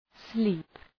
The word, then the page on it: sleep: sli:p